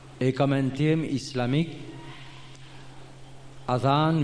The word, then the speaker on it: أذان: Sa vraie prononciation, c'est Adhan avec Zal qui nous prononce dans bout de la langue. Mais en créole, nous y dit Adhan.